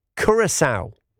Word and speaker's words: Curaçao: Steve, just so you know, your World Cup winning team in the sweepstake is pronounced Curaçao. Thank you, Shelley. So now I know that is good news. Curaçao, they're Steve's team.